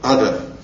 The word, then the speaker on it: адха: На санскрите они называются адха. Адха. Буква Х не читается в санскрите, поэтому ада.